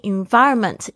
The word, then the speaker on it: environment: Put yourself in an all English speaking environment. Environment 要注意一下大家 V 的发音哦，这个是 V 的发音。 Environment 必须要感觉到你的上排牙齿轻轻碰到下排下嘴唇，然后发出这个 V 的声音。发现很多的学生呢会发成我的声音，不是 environment。